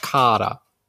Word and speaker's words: Kara: The correct pronunciation is Kara. – Kara, yeah. – Because when I looked at the Japanese subtitles with the Japanese dub, and the ka sound is elongated.